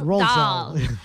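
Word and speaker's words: Roald Dahl: I think you have to say it like rolled. Rolled. Rolled, rolled Dahl.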